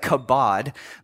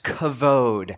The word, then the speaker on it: kavod: The word glory in the Hebrew language, I know it looks like kabod, but it's actually pronounced kavod.